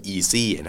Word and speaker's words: EZ: จริงๆเขาอยากให้ออกเสียงว่า Easy นะ